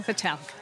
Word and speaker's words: Satel: Satel.